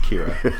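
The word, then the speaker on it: Qi'ra: It's spelled crazy, but it's pronounced Kira.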